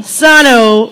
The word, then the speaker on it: Sano: She pointed out that we were pronouncing that wrong earlier. – Sano Wellness and Massage.